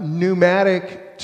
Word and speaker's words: pneumatic: If you ever wonder why pneumatic starts with the letter P, it's because in the Greek, the word pneuma is air. And you pronounce it in the Greek. In the English, the P is silent.